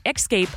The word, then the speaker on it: Xscape: Actually, the band goes by Xscape. If you look up interviews, it's the letter X, Xscape, and they are called Xscape. That's why I pronounce it that way for that particular group.